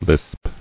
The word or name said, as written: Lisped